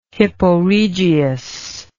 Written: hipporegius

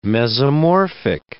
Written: mesomorphic

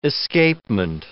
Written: escapement

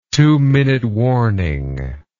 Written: two minute warning

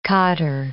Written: khaddar

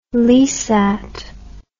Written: Leasat

